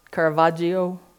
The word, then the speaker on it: Caravaggio: Rest on the Flight to Egypt was also the first biblical painting done over 400 years ago by Caravaggio. Did I say that right? Caravaggio? Yeah. Okay.